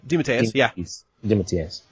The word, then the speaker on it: DeMatteis: DeMatteis. Am I pronouncing that right? – DeMatteis.